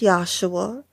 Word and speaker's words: Yahshua: The name Jesus would have been pronounced Yahshua, and it means Savior.